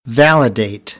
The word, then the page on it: validate: American English